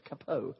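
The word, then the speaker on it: Cappeau: His name was Placide Capot. That's the way you pronounce it if you're French. Capot. Placide Capot.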